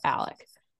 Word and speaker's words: Alex: So his name is Alex on paper, but it's pronounced Alec.